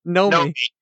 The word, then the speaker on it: Nomi: Naomi. – You like adding syllables tonight. – I know. What's her name? – Nomi. – So she's like a gnome.